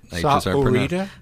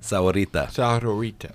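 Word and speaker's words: Sahuarita: I don't pronounce the H. – That's right, because it's Sahorita. – Sahorita. Yeah, it's close.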